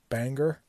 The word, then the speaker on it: Bangor: You may hear some people pronounce it Bangor, but here in Maine we pronounce it Bangor.